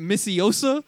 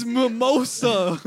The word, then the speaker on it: mimosa: He said, is it Missiosa? – Missiosa. – I said, no, it's Mimosa.